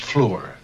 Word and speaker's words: fleur: Bathed every vein in swish liqueur, which virtue engendered is the flower. – Chip, I think that's pronounced fleur. You know, so it rhymes with liqueur.